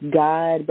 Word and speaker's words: GAAD: I was a part of, it's pronounced God, but it's spelled G-A-A-D.